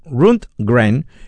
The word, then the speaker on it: Rundgren: Es Todd Rundgren, nunca lo digo bien, Rundgren, el gran productor y músico que termina produciendo también estos temas.